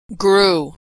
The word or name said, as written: grew